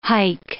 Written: hike

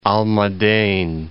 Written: Almaden

